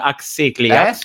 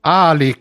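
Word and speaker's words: Alyx: Axiclias. – Eh? Alex.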